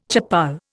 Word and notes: chappal: Indian

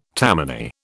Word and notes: Tammany: British English

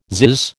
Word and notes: zizz: American English

